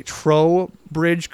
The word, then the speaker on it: Trowbridge: We have Dusty from Virgis, Minnesota, with, I'm going to pronounce this correctly, Trowbridge Creek Zoo. – That's correct. – I always want to say toe, but there's an R in it.